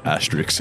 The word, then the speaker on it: asterisk: Massive asterisks. – Glad you said asterisks. – Yeah. Asterisk. Have I pronounced that right? – Yeah, that was perfect.